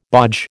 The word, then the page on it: bodge: American English